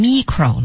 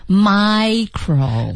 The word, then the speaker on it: micro: 您可千万别读成 micro 了，不是，它的读音是 micro。 – 那两者之间有什么区别呢？在发音上？ – 区别是啊，第一个大的读音是 macro。梅花 a 音，小的呢是 micro，是个元音 i， micro，是的，所以一个大一个小，哎，大家把发音也要注意一下。